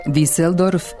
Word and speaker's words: Düsseldorf: Düsseldorf sa S.